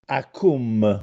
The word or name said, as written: Accùmme